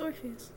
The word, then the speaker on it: Orpheus: Um, the northern winds that could fly, uh, uh, two divine twins that I can't pronounce, and Orpheus. – Mm-hmm. – I don't. Is that how you? That's how you pronounce that, right? Yeah, it's Orpheus.